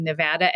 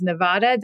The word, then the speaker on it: Nevada: We have had so much documentation of Nevada being Nevada, and so much has been written or joked about its mispronunciation as Nevada that I think it's unlikely that it will shift.